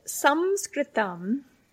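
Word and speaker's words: Samskritam (संस्कृतम्): The Samskritam, the Sanskrit alphabet, Samskritam is how you say Sanskrit in Sanskrit, means literally to put together the subtle form.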